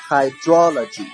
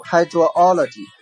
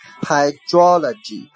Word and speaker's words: hydrology: 往下这个单词啊读作 hydrology hydrology，有的人啊读 hydro 然后他就顺着往下读 hydrology，这样是不对的啊，它的重音在 d 前面 hydrology。